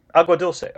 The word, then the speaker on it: Agua Dulce: Meanwhile, in Agua Dulce, did I pronounce that correctly? It's Agua Dulce, I think? – Agua Dulce, okay.